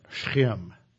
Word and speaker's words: Shechem: And what Greg was pointing out, as we stood on Mount Gerizim, you can look down and you see Shechem. That's how the Hebrew pronunciation, there's no E at the beginning, it's just S-H S-C-H-E-M, Shem.